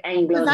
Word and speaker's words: Englund: think it's like, is it pronounced just England? I, it goes L U N D. – Yeah. It's like England.